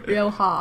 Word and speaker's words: Rioja: Rioja. No, I said that wrong again. Rioja.